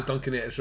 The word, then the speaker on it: Duncanetta: This is Duncanetta's revenge. – Duncanetta. Rhymes with Viennetta.